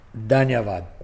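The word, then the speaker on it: dhanyavad: So if I can say it, danyavad. Did I say it right? – Yes, you did.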